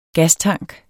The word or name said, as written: gastank